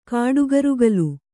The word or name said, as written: ಕಾಡುಗರುಗಲು